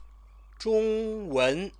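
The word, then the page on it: 中文: Zhōngwén; Chinese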